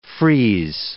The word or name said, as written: frieze